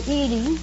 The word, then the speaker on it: Edie: Oh, do they call you Edith or Edith? – They usually call me Edie. – Edie? – Mm-hmm. I'd like to have you call me Edie, if you don't mind.